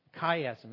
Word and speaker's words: chiasm: Chiasm, it's spelled C-H-I-A-S-M.